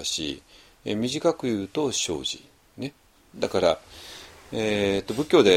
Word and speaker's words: 生死: これね、何回もお願いしてますけれども、まあ、一般の日本語だと生死ですね。生、生きることと死ぬことですね。生死って、生死っていう発音しますけれども、仏教では生じって読みますが、これちょっと生じっていうこと、発音を覚えちゃってください。っていうのは、ちょっと仏教的に生死について考えるときは、必ず生じって、いう発音をしますのでね。